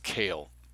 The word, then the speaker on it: Koehl: When NSWPP Commander Matt Cale, K-O-E-H-L, and it's not Cole, it's pronounced Cale, reorganized the party as the New Order in 1983, Mr. Kerr was one of its founding associates.